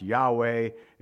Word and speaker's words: Yahweh: It's usually pronounced Yahweh, and it's somehow become Jehovah.